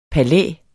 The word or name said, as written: palæ